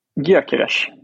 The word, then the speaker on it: Gyökeres: Hur uttalar du honom? – Gyökeres. – Ja, det är bra. Det sitter. Ja.